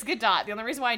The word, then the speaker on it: Gadot: It's Gal Gadot, which is weird. – Yeah. I'm sorry. – It might be Gadot, but it's you pronounce the T.